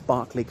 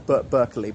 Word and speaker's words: Berkeley: And that's why there's this Berkeley Castle. I know it looks like Berkeley, but pronounced Berkeley.